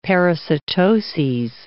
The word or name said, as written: parasitoses